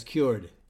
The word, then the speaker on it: Kyoord: It is spelled K-Y-O-O-R-D. And they pronounce it as cured.